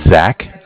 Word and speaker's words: Zach: Yeah, my name is Zach, spelled Z-A-C-H. – Okay. – I'm calling from Bank of Cardiff. Cardiff is spelled C-A-R-D-I-F-F, like Cardiff, Wales.